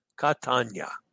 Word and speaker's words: Catania: All right, the last Chantier, I said that wrong. I see Dennis. Catania. Yeah, I tried to do that too fast.